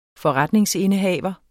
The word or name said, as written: forretningsindehaver